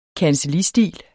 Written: kancellistil

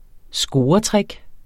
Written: scoretrick

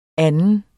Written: anden

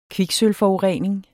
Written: kviksølvforurening

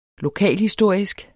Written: lokalhistorisk

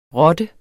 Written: rotte

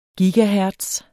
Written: gigahertz

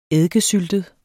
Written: eddikesyltet

